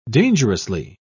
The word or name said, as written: dangerously